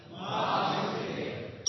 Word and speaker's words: Vasudev: Everyone say that beautiful name. – Vasudev. – Yes, Dwarkadish is here. He'll make sure that we pronounce it properly. Vasudev. Everybody? – Vasudev. – How did we do, Dwarkadish? Correct? Very good.